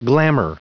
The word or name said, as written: glamor